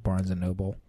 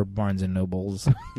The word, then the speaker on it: Barnes & Noble: I'm more interested in Jonesy's pronunciation of Barnes & Noble. Or Barnes & Nobles. – Yeah. – Is it not Nobles? – I don't think it's Barnes & Nobles. It's definitely not Nobles, and I don't think it's Nobles.